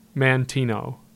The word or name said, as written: Mantino